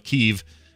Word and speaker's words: Kyiv: Or K-I-E-V. – Yeah, K-I-E-V, that's what it was. E-V, yeah. Now it's K-Y-I-V. That's cool. – And it's now pronounced Kyiv. – All right, Kyiv. Let's do it right then.